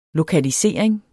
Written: lokalisering

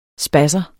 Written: spasser

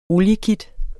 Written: oliekit

